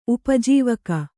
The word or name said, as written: ಉಪಜೀವಕ